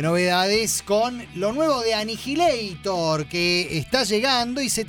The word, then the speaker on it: Annihilator: deberíamos decirle Annihilator. – Bueno, pronúncielo usted, a mí no me sale así. – Sí, Annihilator.